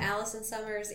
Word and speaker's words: Allison Summers: at Allison Summers Insty uh, on Instagram with two L's and two M's.